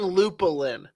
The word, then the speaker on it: Lupulin: If you missed a loop, you keep saying lupulin, like Rufulin. It's Lupulin. That's how it's pronounced.